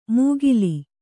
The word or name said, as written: ಮೂಗಿಲಿ